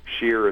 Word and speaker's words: Shiyr: Of course, the thing is, Sheer is spelled S-H-I-Y-R.